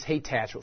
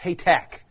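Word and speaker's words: Hathach: And you think it's Haytach. Well, it's Haytack. It sounds like a K on the end.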